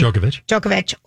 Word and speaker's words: Djokovic: Djokovic, or Rafael Nadal in the final.